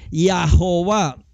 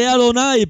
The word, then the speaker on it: Adonai: Y no es como todo lo que hoy están diciendo, porque muchos dicen, no, aquí se les Yahová. No, se lee Adonai, porque ahí tiene las vocales de Adonai.